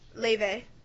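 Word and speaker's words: Laufey: Leve. – Laffy or Laffy? – What? – Like, how do you pronounce it? – Leve. – That's how you pronounce Laffy? – Yes. Her name is Icelandic.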